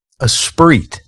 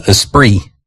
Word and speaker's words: Esprit: Esprit. – Yeah, Esprit. – Esprit. – Yeah, I don't think you pronounced the T part of it.